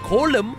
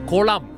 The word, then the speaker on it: Kolam: Now, it is pronounced Kolam, not Kolam. Kolam is a pond that you have in your house, alright? Kolam is the art.